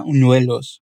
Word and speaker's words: buñuelos: Repare que a pronúncia da Paola aqui, neste trecho, ela fala com muita naturalidade e de forma tão suave que o B quase desaparece na pronúncia. E fica unuelos.